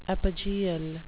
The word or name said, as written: epigeal